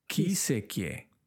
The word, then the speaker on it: que isso é que é: And really, all these words, we're going to have to do them together because they all melt into one. Que isso é que é, and that sounds like really well, but we never say it like that. So let me tell you the word we actually say.